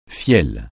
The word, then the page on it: fiel: francais